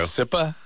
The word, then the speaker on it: Tsipa: Sipa. – Sipa? Sipa. – Hi, Sipa. – Sipa. – Sipa, you're 24. What kind of name is that? – It's Russian Jewish, and actually it's spelled T-S-I-P-A, but...